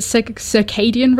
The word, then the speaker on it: circadian: Is it circadian rhythm? Am I pronouncing that right? – That's right.